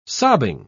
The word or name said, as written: sobbing